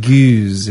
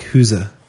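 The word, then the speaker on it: gueuze: You can pronounce it goose if you like. But I like huza. It's G-U-E-U-Z-E.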